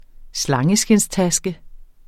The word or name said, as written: slangeskindstaske